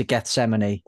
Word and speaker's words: Gethsemane: Vastum inward to Gethsemane. Oh. – Yeah, you said it right.